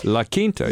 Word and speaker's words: La Quinta: So while they were all in quarantine there at that La Quinta, is that how you say it? – La Quinta, La Quinta, before they started filming, she got a list of all the guys that were going to be there as suitors, okay? – And credit checks.